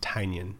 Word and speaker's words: Tynion: Is it Tynion? – I thought it was Tynion. I heard Scott Snyder say it on a podcast, and he pronounced it Tynion.